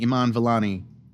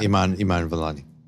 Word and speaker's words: Iman Vellani: The point is, if you look at the very casting, Iman Vellani as as that as that part, that's how you pronounce her name, right? – Iman, uh, Vellani, yeah. yeah, Iman Iman Vellani.